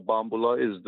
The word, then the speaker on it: bambula: In New Orleans, the rhythm that they call bambula in New Orleans, accent on the second syllable, was the rhythm that it was the Antillean beat.